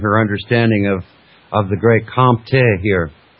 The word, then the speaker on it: Comte: But although Henry St. Simon coined the term industrial and introduced the notions of positive and philosophie positive before Auguste Comte, it was Comte, C-O-M-T-E, I'm just going to say Comte, it's pronounced Comte, like you're just swallowing your tongue.